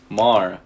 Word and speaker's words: Maher: Maher. It's Bill Maher. – Oh, it is. No, it's. Oh, it's spelled Maher with an H.